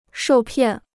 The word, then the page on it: 受骗: shòu piàn